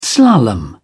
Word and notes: slalom: British English

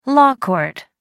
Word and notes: law court: American English

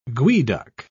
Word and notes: geoduck: British English

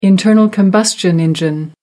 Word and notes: internal-combustion engine: American English

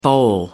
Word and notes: bole: American English

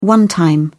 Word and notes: one-time: British English

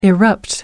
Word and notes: erupt: American English

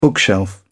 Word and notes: bookshelf: British English

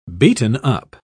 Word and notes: beaten-up: British English

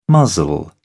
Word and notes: muzzle: British English

